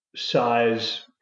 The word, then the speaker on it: scythes: So they're like these sort of papery looking yellow giant insects with size for arms or scythes, or how is that pronounced? This is the problem when you learn words from books. I never know how to pronounce anything. – Size.